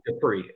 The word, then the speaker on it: Dupree: It is Dupree. I know it doesn't look like how we would normally spell Dupree, right, like Marcus Dupree, but no, it is pronounced C.J. Dupree.